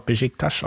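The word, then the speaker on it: Beşiktaş: Ansonsten war, glaube ich, relativ klar, dass Besiktas äh, nicht, oder Besiktas, wird doch Besiktas ausgesprochen, glaube ich. – Besiktas. Genau, ähm, ja gut, äh, haben wir ja Dortmund schon, haben wir schon drüber geredet.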